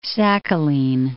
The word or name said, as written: Sakhalin